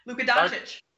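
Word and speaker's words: Luka Dončić: Luka Doncic. – Say it one more time. – Luka Doncic.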